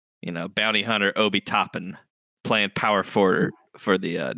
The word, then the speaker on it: Obi Toppin: Tell me about tell me if I pronounce this correctly, but tell me about Obi Toppin. – Obi Toppin. – Obi Toppin, that's right.